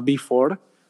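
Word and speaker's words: Be4: Es una idea de negocio que se llama Before. Se escribe B grande, doble E y el número 4 y el, nombre puedo resumirlo en que es una conjugación divertida de la palabra before,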